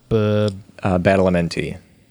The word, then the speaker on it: Badalamenti: Angelo Badalamenti.